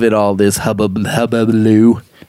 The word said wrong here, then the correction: hullabaloo: How do you say that? Hubbaloo, hullabaloo. – Hullabaloo. – Hullabaloo. Hullabaloo. Yeah.